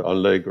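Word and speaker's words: anlæg: word is spelled A N L A E G with that A E as a joined letter, Anleg or something.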